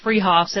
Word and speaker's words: Freehoff's: Spelled Freehoff's, pronounced Freehoff's, at 33rd and Arapahoe in Boulder.